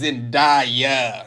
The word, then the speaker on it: Zendaya: He goes, oh, it's Zendaya.